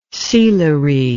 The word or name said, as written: sealery